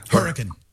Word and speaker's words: Hurricane: And I don't think it's pronounced Hurricane. That's an interesting name. – Hurricane.